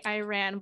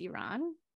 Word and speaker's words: Iran: I used to say Iran, but I think the correct pronunciation is Iran.